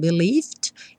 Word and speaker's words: believed: O believe, eu não pronuncio é, então believed.